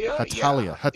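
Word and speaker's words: Hetalia: Heshalia Axis Powers? – Oh, boy. – Am I reading that correctly? – Hatalia.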